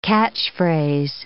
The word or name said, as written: catchphrase